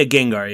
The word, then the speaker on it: Gengar: Is it Gengar? – Yeah, Gengar. Yeah, he's the purple ghost Pokemon. Yeah. – Okay, so you have what I'm assuming is the world's largest Gengar collection? – Probably, yeah.